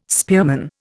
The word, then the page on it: spearman: Indian